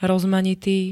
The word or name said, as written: rozmanitý